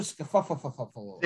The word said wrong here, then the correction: Schauffele: Xander Schauffele. Yes, I, I always butcher this poor guy's name.